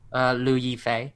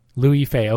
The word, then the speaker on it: Liu Yifei: Liu Yifei. – Liu Yifei, okay.